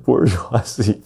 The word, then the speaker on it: bourgeoisie: Bourgeoisie, bourgeoisie, bourgeoisie, bourgeoisie. That's right, bourgeoisie.